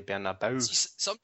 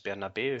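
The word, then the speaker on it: Bernabéu: We normally say the Bernabeu, but that's not how you say it. It's Bernabeu.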